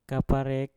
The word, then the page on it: kapal rek: Mpur